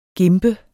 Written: gimpe

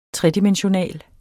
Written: tredimensional

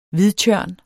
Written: hvidtjørn

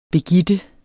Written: begitte

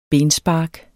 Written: benspark

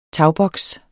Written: tagboks

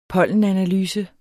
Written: pollenanalyse

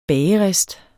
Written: bagerist

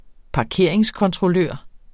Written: parkeringskontrollør

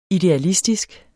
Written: idealistisk

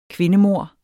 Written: kvindemord

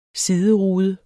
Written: siderude

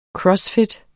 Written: funktionel

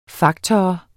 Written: faktor

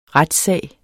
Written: retssag

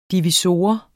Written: divisor

